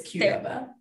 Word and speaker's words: Qdoba: Qdoba, it is Qdoba.